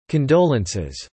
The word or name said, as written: condolences